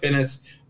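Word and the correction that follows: Finneas: Yeah, Phineas. – I knew I said that wrong.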